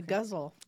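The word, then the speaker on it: ghazal: Yeah, the guzzle. – Guzzle. – Guzzle, just like guzzle.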